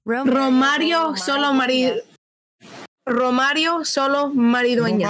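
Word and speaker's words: Xolo Maridueña: Maridueña. – How do you say the name? – Yeah. Solo Maridueña.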